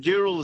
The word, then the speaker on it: Dural: It's spelled D-U-R-A-L, so that's Dural. That's the term that it, you know, colonised, but it actually stands for D-O-O-R-A-L. That's twice Dural, which is burning log.